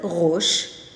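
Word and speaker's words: Rosh (ראש): The word Rosh, spelled Resh Aleph Shin, you don't hear the Aleph is silent, but you still have to write it.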